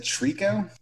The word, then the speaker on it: Tricot: It's spelled T-R-I-C-O-T, like tricot. – Oh, okay. – That's how we were pronouncing it for so long. You're like, oh yeah, I love that band Tricot, and then we watched their audio tree and we learned.